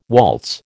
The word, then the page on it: waltz: American English